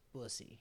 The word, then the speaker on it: Bussy: I thought it was bussy. Rhymes with pussy, but it's bussy. But it's spelled B-U-S-S-Y.